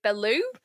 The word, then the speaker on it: Bellew: How do you say his last name? Tony Bellew? – Bellew. – Bellew. Thank you. Yeah, that's quite obvious now you've said it.